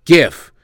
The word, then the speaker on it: GIF: They say it's pronounced JIF, but JIF's a peanut butter. GIF of Travis Kelce dancing.